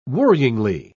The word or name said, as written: worryingly